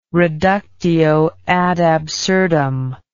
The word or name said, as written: reductio ad absurdum